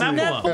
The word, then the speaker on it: Niflheim: It's Niflheim. – It's Niflheim.